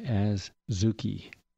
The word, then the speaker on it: adzuki: And finally, when was last time that you had adzuki beans? A-Z-U-K-I, adzuki. Though I've also heard it pronounced adzuki, but it's adzuki.